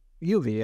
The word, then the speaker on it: Ubi: How do you say Ubi? – Yeah. Ubi? Ubicloud, yeah. – Ubi, okay. Ubi. Yeah, yeah, like Ubisoft.